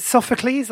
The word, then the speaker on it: Sophocles: Is it Sophocles? That's how you say it, isn't it? – Sophocles. – Yes, yeah. It is.